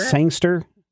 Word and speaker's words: Sangster: Brody Sangster, I think is how you say his name. – Sangster? – Sangster. Yeah. – Past tense. – Yeah. S-A-N-G-S-T-E-R.